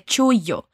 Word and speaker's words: chullo: una vez más y atención a la pronunciación de chullo.